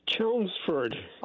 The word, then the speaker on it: Chelmsford: It's Chelmsford.